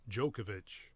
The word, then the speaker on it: Djokovic: Djokovic. – Yes. So forget the D.